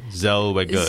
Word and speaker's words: Zellweger: How do you pronounce that name? Zellweger. – Zellweger.